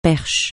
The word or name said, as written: perche